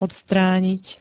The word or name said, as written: odstranit